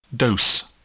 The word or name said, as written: dose